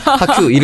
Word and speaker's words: 학규: 학큐 이름 발음에 학큐잖아요.